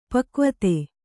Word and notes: ಪಕ್ವತೆ: pakvate

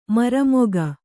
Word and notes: ಮರ್ಮಿಗ: marmiga

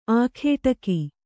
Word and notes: ಆಗಡಿಗ: āgaḍiga

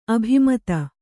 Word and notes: ಅಭಿಮತಿ: abhimati

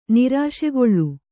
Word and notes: ನಿರಾಶೆಗೊಳ್ಳು: nirāśegoḷḷu

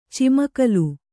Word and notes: ಚಿಮ್ಮುಗಾಲ: cimmugāla

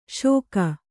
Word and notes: ಶೋಕ: Kannada

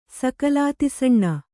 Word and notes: ಸಕಲಾತಿಸಣ್ಣ: kannada

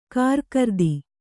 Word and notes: ಕಾರಕಿರಿದಿ: kārakiridi

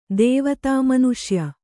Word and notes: ದೇವತಾಮನುಷ್ಯ: kannada

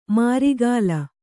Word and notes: ಮಾರಿಗಾಲ: Kannada

